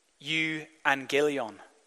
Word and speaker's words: euangelion: It's the Greek words, I'll try and pronounce it, euangelion. And it comes from two words, eu and angelion. And eu means joy or goods, and angelion means message.